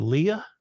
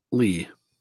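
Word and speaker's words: Leigh: Is it Leah or Lee? – Lee.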